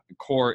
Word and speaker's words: Corps: So Casey, if you're not familiar, Casey has a hardcore marketing podcast, and core is spelled CORPS. – Right. The only way to spell that. Somebody corrected me the other day. I was telling people, hey, it's hardcore marketing. It's spelled like the Marine Corps, not the Earth Corps. – Mm-hmm. – And the guy says, yeah, but it's spelled with an S. He's like Marine Corps, he's like hard corpse marketing.